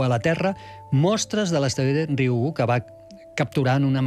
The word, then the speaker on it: Ryugu: Ryugu. És molt fàcil, com una I, Ryugu.